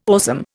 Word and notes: awesome: Indian

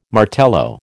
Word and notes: Martello: American English

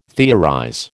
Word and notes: theorize: American English

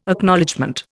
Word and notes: Acknowledgement: Indian

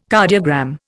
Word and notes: cardiogram: Indian

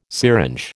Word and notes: syringe: American English

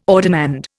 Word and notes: ordinand: Indian

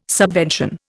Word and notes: subvention: Indian

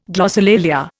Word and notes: glossolalia: Indian

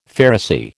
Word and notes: Pharisee: American English

